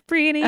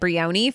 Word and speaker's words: Brienne: Did I say her name right? – Yes, Brienne. – Okay, good. – You have to rhyme it with peony, because I said it Brioni for like 2 years until I said it in public.